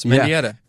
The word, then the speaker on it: Mendieta: Is that how you say it? – Yes, Mendieta.